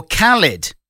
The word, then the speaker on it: Khalid: The name Khalid is commonly pronounced Khalid or Khalid. I don't know what the difference between those two are. Rhymes with Gleed, yeah. While some sources argue for Khalid, which rhymes with salad. – Salad. – Pronunciation, the American singer Khalid Robinson has personally clarified his name is Khalid, so you were right.